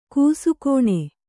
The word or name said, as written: ಘಸಕ್ಕನೆ